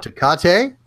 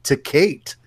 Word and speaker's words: Tecate: You can pronounce it Tecate, or you can pronounce it like some of the folks back home, Tecate.